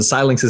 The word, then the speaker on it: Xilinx: I was always confused with it, but Xilinx is definitely the correct way.